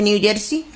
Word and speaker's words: New Jersey: Wow, pues, ¿se dice así? ¿Se dice New Jersey? – Sí.